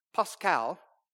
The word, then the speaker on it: Pascal: Pascal, really how you say it, Blaise Pascal, was this brilliant mathematician who lived during the so-called Enlightenment.